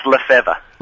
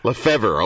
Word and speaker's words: Lefebvre: Is it Lefebvre or Lefebvre? I could never pronounce that guy's name. – It's Lefebvre. – Lefebvre, okay, all right.